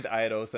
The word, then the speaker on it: Eidos: Eidos or Eidos? – Eidos. – Oh, Eidos, yeah. – Yeah, and I'm like, I didn't know that was... I had no clue. It was Square owned Eidos until, like, the past year or so.